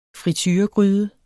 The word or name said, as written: frituregryde